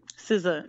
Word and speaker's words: SZA: SZA, is that right? – SZA. Mm-hmm. Yeah, you said it right.